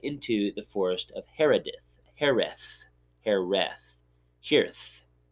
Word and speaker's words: Hereth: H-E-R-E-T-H. – Hereth, yeah. – Hereth. Kind of like Harris, but with a lisp. Hereth. – So there we go. Mike Tyson was saying it. – Yeah. – Hereth.